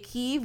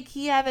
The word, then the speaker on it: Kyiv: So we say Kyiv, we don't say Kiev anymore because that's the way the Russians pronounce it and that's not the way they pronounce it to begin with.